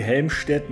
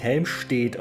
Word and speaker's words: Helmstedt: Genau wie Helmstedt nicht Helmstedt ausgesprochen wird.